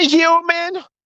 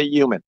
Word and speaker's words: human: Hi, human. Hi, human. You pronounce the H? Some people don't. They say human.